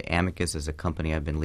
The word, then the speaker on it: Amicus: We pronounce Amicus.